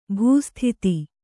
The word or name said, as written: ಭೂಸ್ಥಿತಿ